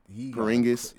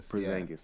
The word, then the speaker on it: Porzingis: Porzingis? – Yeah. – Porzingis, yeah.